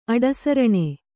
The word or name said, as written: ಅಡಸರಣೆ